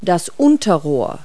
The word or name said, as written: downtube